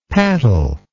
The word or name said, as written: pattle